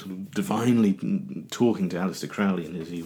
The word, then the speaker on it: Crowley: That wonderful, weird magician, Aleister Crowley, or Crowley, I should say, rhymes with holy, that's what he always said, Aleister Crowley, stated that 11th is the number of magic in itself.